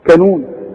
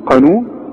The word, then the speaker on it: قانون: وعندك بقى في الكلمات البلد بتاعتنا بقى بنت المتداولة، عمرك ما تقول على القانون كانون، تقول كانون، قانون، وتقول قابوس، ما تقولش ايه، هتقول كابوس، قابوس، قرار، ها، قرض، خلاص.